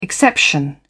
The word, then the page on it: exception: British English